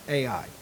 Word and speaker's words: Ai: Well, because of Achan's greed, the next battle that they were going to fight with a people called Ai, and that's exactly how you spell it, Ai, and that's how you pronounce it, Ai.